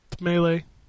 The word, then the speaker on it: melee: Because you're wrong. It's Melee. It's Melee.